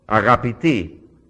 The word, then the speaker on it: agapiti: Agapiti is the way you pronounce it. If I was talking to all of you, I would say agapiti, beloved ones.